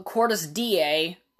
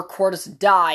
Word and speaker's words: Cordis Die: And, so then we go to the 2025 portion where he has pretty much built up this organization called Cordis DA, or Cordis Die is really what it says, but it's pronounced DA, and he essentially has funded terrorist groups all around the world.